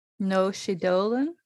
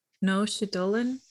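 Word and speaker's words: Naoise Dolan: We have Exciting Times by Noshi Dolan. – Noshi Dolan. Yeah, that's probably how you pronounce that.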